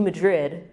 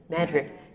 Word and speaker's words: Madrid: And then on to New Madrid, which I've been told I'm mispronouncing, so anyone from Missouri can correct me. But to New Madrid, how do you say it? Rick, you're nodding. Madrid. New Madrid, Missouri.